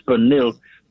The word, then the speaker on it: pernil: That's how you say it in Spanish, pernil, pork shoulder.